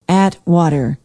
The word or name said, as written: atwater